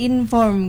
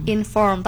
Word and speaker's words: inform: Kalau inform sendiri dia tuh ditekan di akhirnya gitu. Jadi kayak inform gitu. – Inform. – Tapi R-nya juga agak-agak samar ya kayaknya ya.